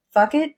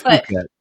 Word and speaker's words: Phuket: Fuck it. Is it actually pronounced fuck it? Because no. I've always heard Phuket. It's Phuket. Okay.